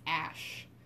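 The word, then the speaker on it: Æ: Like A-E is Ash. It's like pronounced Ash.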